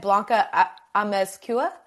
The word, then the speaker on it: Amezcua: Blanca Amezcua? – You got it.